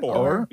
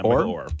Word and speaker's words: Oher: Is it Oher? Is that how you say it? – Oher. Yeah, Oher.